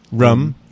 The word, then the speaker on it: room: Um, and I know some people say that the one word you can tell I'm a Bostonian is how I pronounce R O O M. – Rum. – Yeah, I say rum, ah. which is like a very Boston thing.